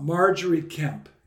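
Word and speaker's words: Margery Kempe: But in your book, we get a lot about Marjorie Kemp. Is that, if that's the way to pronounce it? – It is.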